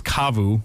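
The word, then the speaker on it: CAVU: Now, I believe it's Kavu because I'm an idiot and I do not always know how to pronounce things correctly, but it's C-A-V-U.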